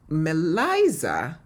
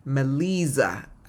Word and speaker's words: Meliza: Meliza, and I say Liza, Meliza, forgive me, because I'm going to say it again. Meliza.